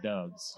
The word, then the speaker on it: Doubs: He was Dobbs is not pronounced Dobbs? – D-O-U-B-S. – Okay. – In Nevada, we call that Dubs.